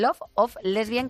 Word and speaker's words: Love of Lesbian: Love of Lesbian, que es un grupo que hay que pronunciar así, separado, porque si no, Love of Lesbian, sí, ya, y no te sale.